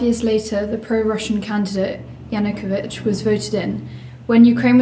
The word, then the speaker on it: Yanukovych: Yeah, Yanukovych.